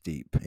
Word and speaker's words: dib: It's spelled D-I-B, but it's pronounced deep.